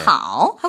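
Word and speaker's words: 하오 (好): 턱을 내렸다가 올리니까 삼성. 네. 하오 하고 발음을 합니다.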